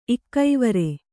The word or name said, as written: ಇಕ್ಕೈವರೆ